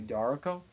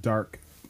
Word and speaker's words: DARQ: The game's name I was talking to, which I seen on Reddit as an indie game, but his name is D-A-R-Q, Darko. But I don't know. – Oh, so it's called Dark. – Dark. – But it's spelled D-A-R-Q.